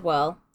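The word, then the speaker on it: Wehrle: I don't know how to pronounce this. Whirl. – W-E-H-R-L-E. I said Whirl.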